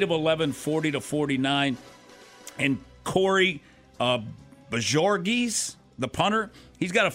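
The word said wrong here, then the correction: Bojorquez: So Bajorquez.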